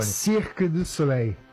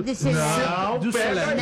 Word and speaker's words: Cirque du Soleil: Por favor, como é que, qual que é a pronúncia correta em francês, você que esteve lá durante muito tempo? Fala pertinho do microfone. – Cirque du Soleil. – Ah, eu falei certo. Cirque du Soleil. Não falou.